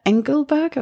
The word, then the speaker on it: Engelberg: So you did the, you went to Engelberg. Have I pronounced that right? – Yeah, I think so.